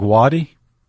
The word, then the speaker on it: Watie: how do you pronounce that last name? – I think Wadi. – W-A-T-I-E, and Elias Boudinot.